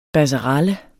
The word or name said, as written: BASSERALLES